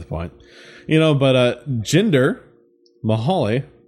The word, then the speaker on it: Jinder Mahal: Dude, I can never get his name right. – I gotta say, I'm proud of you. – Yeah. I was calling him Ginger. Not Ginger. Yes.